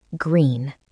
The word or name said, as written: green